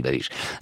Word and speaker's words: Babiš: Dans le monde, le, Premier ministre euh, tchèque, Andrej Babiš, on dit Babiš, c'est ça Babiš. Ça s'écrit B-A-B-I-S, on dit Babiš, justifie la position des États d'Europe centrale sur les migrants, c'est l'affaire sensible en ce moment en Europe.